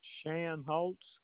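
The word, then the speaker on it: Shanholtz: David Shan Holtz.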